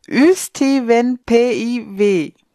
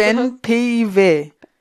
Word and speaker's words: Ystävänpäivä: Ich habe mich vorbereitet. Ystävänpäivä. So wird's ausgesprochen. – Ja, wahrscheinlich, ja. Also zumindest doch, haben wir, so. Ich genau, habe geübt heimlich. Wird's so ausgesprochen? Man kann mir glauben.